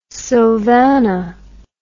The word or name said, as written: sylvana